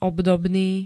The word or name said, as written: obdobný